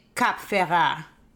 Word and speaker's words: Cap Ferrat: How do you say that place? – Cap Saint-Jean-Ferrat, but I think some people just say Cap Ferrat.